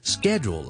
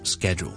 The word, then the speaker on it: schedule: 咁啊，其实咧系点咧？schedule 咧？就是美國英文的發音 schedule，OK、 okay? schedule 呢我之前嗰個發音是 share s h a r e 咁啊，差唔多讀去 share 就得啦，OK schedule 呢個就是英式英文的發音，OK 所謂的 BBC English 啦，咁所以就冇話錯唔錯嘅 schedule 或者 schedule again British English schedule 美式英文 schedule，OK、 okay?